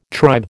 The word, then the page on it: tribe: American English